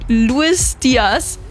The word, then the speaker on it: Luis Diaz: In ihrer Story bedankt sie sich beim FC Bayern-Spieler wie Luis Diaz, Harry Kane, Jamal Musiala und Alfonso Davis. Habe ich alles richtig ausgesprochen, oder? – Ja, korrekt.